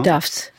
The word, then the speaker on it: Tufts: Vous êtes endocrinologue, professeur donc de biologie moléculaire, entre autres à l'université Tufts. C'est comme ça qu'on prononce. – Oui, c'est Tufts.